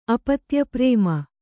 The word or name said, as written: ಅಪತ್ಯಪ್ರೇಮ